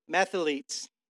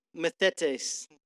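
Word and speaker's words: mathetes: So I looked up the word disciple, and going back into Greek, and is methetes or something, mathletes. I don't know. Something like that. I just said mathletes, I think. I don't think that's the right word, though. Methetes.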